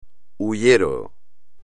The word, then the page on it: hullero: espagnol